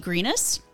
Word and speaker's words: Grenus: And then the fabulous lady next to Melody Judge is Dusti Grenus. Did I say that correctly? – Grenus.